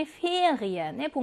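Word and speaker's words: Ferien: 방학이라는 의미지니 ferien의 복모음 이에는 이어로 발음을 한다.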